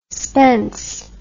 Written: spense

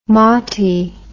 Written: motty

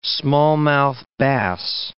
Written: smallmouth bass

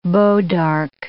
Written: bois d'arc

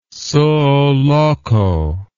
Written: suo loco

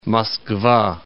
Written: Moskva

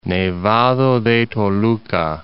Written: Toluca, Nevado de

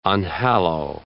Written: unhallow

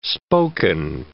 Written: spoken

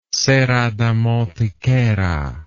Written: serra da mantiqueira

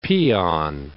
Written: peon

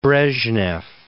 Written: Brezhnev

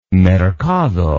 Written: mercado

